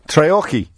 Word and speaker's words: Treorchy: Treorchy, is it, Rob? – It is, yeah. – Did I pronounce it right? – Yeah, you did.